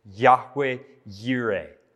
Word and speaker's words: Yahweh Yireh: And as God reveals this name of God in the Hebraic pronunciation, it would have been Yahweh Yireh. Yahweh Yireh.